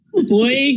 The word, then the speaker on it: Beug: Well, Well, it's you got it halfway in between. Beug is German and is English, so you kind of got the.